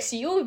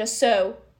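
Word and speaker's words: sew: Когда я развивала блог, делала там видео, посты, про м, тематики именно швейного английского, про произношение, например, слова so, которое пол инстаграма, если не больше, произносили как сью, вики сью, вики сьюз, да. Давай ты нам э, еще раз повторишь, как мы должны произносить конкретно, например, компанию э, Соус мы произносим как соус, больше как, ну, соус, э, как соус, как кетчуп, майонез, это соусы, да, ну вот. Соответственно, сшить это тоже so, да, so it now, so...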